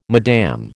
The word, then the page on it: Mme: American English